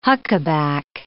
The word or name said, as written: huckaback